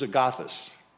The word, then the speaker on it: agathos: The first one, in its English translation, is spelled A-G-A-T-H-O-S, agathos, agathos, A-G-A-T-H-O-S.